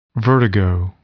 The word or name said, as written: vertigo